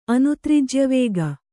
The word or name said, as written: ಅನುತ್ರಿಜ್ಯವೇಗ